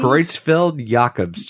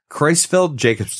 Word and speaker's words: Creutzfeldt-Jakob: And actually, it's got another name, which I don't even know how to say this properly. Creutzfeldt-Jakob disease? – Creutzfeldt-Jakob's disease. – Uh-oh, I was way off. – Creutzfeldt-Jakob's disease.